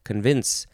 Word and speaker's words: convince: Second syllable is emphasized, convince.